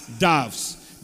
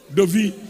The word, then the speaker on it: dove: But the right pronunciation is dove, not dove. Or Gus also say dove. Gus also says dove. Okay. Nigeria, what about Nigerians? Dovi. The airways say Dovi. But it is dove.